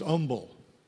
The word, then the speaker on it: humble: In the South, they say he was humble. The H is silent.